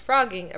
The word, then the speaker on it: phrogging: Um, it says the crime of individuals secretly living in someone's home is known as phrogging, a reference to how frogs leap from place to place. It's print. that's how it's pronounced. It's spelled P H R O G, not F R O G.